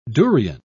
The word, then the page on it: durion: British English